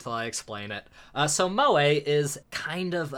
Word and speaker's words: moe: Like moai heads? That's my only frame of reference for anything that sounds vaguely like that. – No, it's spelled M-O-E. – Okay. – So Mo, but Mo-A.